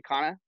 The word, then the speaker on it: Cana: We have, it's called actually we pronounce it Cana, but, you can call it whatever you want.